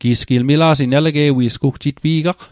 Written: elege'wi'sgw

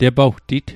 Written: tepawtit

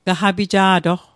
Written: gaqapija'toq

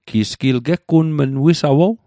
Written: wisawow

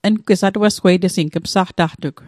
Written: atuasgweitesing

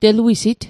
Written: teluisit

